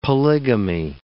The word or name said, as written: polygamy